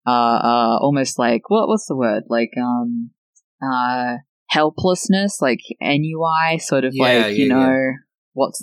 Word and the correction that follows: ennui: Did you pronounce it NUI? – Is it not? Is it ennui? – It's ennui. – Ennui.